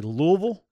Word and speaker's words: Louisville: Did you say Louisville? – I said Louisville. And having lived in Indiana before, I wanted to, that's the proper pronunciation.